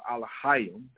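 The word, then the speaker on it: Alahayim (אלהים): To compare Elohim, Yahweh, or Yahweh, this is what they're saying, and it's actually uh, Alahayim, that's how you say it in Hebrew, which means powers.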